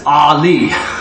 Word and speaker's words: Eli: And Eli is Ali. Telling you, Muhammad Ali, and that is Strong's number H5941.